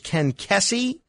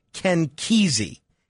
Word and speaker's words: Kesey: I think I said his name was Ken Kesey. Ken Kesey's name is pronounced Ken Kesey. Ken Kesey. My error.